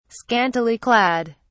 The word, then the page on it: scantily clad: British English